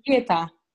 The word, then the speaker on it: Jeta: Jera, uh, am I pronouncing it correctly or should we No, it is Jeta.